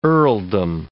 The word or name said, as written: earldom